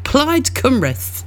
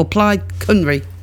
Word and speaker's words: Plaid Cymru: Now, thank you to Adele, who has given me the correct pronunciation. Plaid Cymru. That's it. Or Plaid Cymru.